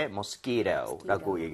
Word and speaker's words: mosquito: 뒤에 t라고 하는 부분은 모음 사이에 t가 있으면. 플랩이라고 해서 리을 비슷하게 발음이 됩니다. 그래서 네. mosquito 해도 틀린 거 아니에요. 그런데도 부드럽게 mosquito 이렇게 하는 거 추천을 드리겠습니다.